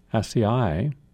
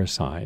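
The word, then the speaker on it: açaí: And also one of those superfoods, ACAI, or ACAI, it's pronounced both ways,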